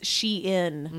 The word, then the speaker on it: Shein: Are we at Sheen? – No. Look. The tag says Shein. Mm. They pronounce it that way.